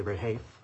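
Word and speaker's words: Rehaif: Unless my colleagues want to pursue that further, I'd like to ask you about the Rahafe issue, if I'm saying that name right. I'm not sure I am. How do you say it? – I say Rahafe.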